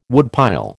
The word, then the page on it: woodpile: American English